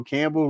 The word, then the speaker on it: Campbell: A lot of people saying Dan Campbell was, you know, Campbell rhymes with gamble, and that's where it goes.